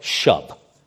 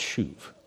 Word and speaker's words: shuv: And if you see it spelled, it actually looks like shub. But it's pronounced shuv.